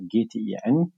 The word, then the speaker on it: GTIN: Ähm, aber offiziell ähm, nennen wir sie mittlerweile ähm, GTIN kurz gesagt oder Global Trade Item Number, also GTIN ausgesprochen.